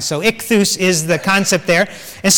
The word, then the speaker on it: ichthus: And so this concept of ichthus, okay, that's how to pronounce it. Let me see if I can get this, yeah. This is an I sound as we have it. This is sort of a K sound. It's a chai.